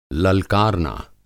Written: ललकारना

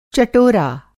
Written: चटोरा

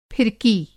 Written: फिरकी

